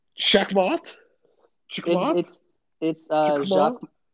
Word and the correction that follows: Jacquemot: In French, you don't pronounce the T.